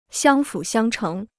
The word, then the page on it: 相辅相成: xiāng fǔ xiāng chéng